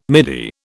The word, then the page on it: midi: British English